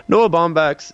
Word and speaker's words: Baumbach: By the way, how do you say his name, the director? Did I say it right? – Oh, I was kind of working on something as you were doing it. It's Noah Baumbach. – Bach. Noah Baumbach. Yeah.